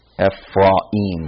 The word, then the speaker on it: Ephraim: This, you might say Ephraim. And then I hear people call it Ephraim. And I try to pronounce this more in a Hebraic way, Ephraim, like the syllable cut between the A and the I.